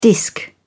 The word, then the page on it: disc: British English